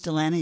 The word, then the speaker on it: Dalana: My name's Delana. You're listening to Music to My Ears on KPTZ. My name is spelled D-A-L-A-N-A.